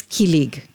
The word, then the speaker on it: kilig: You dedicated one of your chapters to the Tagalog word kilig, K-I-L-I-G. Is that how I pronounce it? – Yes, kilig.